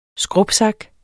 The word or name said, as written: skrubsak